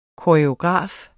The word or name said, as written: koreograf